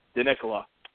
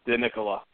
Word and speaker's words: DiNicola: I would like to know how to say your last name. It'd be great. – DiNicola. DiNicola.